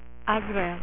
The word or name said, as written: azrael